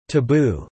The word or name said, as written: taboo